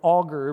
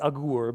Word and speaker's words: Agur: And you might want to pronounce his name like the wood-boring tool, like an auger, but if you want to be technical and, you know, put my nerd glasses on, then it's Agur.